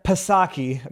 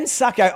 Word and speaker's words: Psaki: I will give Jen Psaki. No, I should say that. I initially was actually pronouncing her name Psaki, and then I was told it's a silent P.